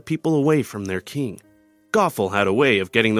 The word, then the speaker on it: Gothel: Her name was Gothel, which rhymes with awful, and she was awful.